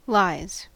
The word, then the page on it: lies: American English